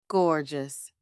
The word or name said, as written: gorgeous